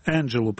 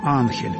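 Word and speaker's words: Angel: Фамилия Энджел пишется так же, как испанская «Анхель» и означает «ангел».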